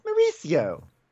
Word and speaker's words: Mauricio: Because I have a friend who just said, Mauricio. And I was like, no, it's pronounced Mauricio.